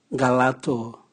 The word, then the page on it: ngalato: Raga